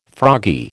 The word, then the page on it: froggy: American English